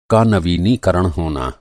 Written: नवीनीकरण

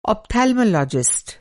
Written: ऑप्थैल्मोलॉजिस्ट

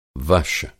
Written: वश

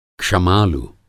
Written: क्षमालु

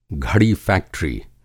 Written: घड़ीफ़ैक्ट्री

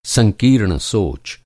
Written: संकीर्ण

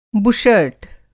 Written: बुशर्ट

